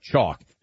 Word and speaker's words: Choq: Chalk. C-H-O-Q, spelled with a Q.